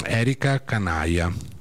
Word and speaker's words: Erika Canaia: Un abbraccio a Andrea Sceriffo da Verona e Erika Canaia, giusto? – Grazie. – Anche l'accento è giusto? – Giustissimo, no? Okay.